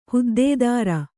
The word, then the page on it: ಹುದ್ದೇದಾರ: huddēdāra